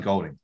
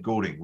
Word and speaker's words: Goulding: I need to start saying Goulding. That's how he pronounces it. People I've known in my previous life have said Goulding, which is why I always stuff it up.